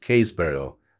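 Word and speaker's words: Happisburgh: The name is pronounced Haysborough, and the modern village overlooks a coastline continually reshaped by erosion.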